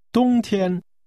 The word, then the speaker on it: 冬天: This is pronounced with two high-level tones. – 再说一次。 – Winter. – 冬天。